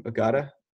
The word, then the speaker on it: Agata: Yeah, no, I thought this was a great story, and I especially like the quote from Kent Ridge coach Brett Agata. Is that how you pronounce his name? Agata? – Mm-hmm.